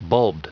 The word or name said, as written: bulbed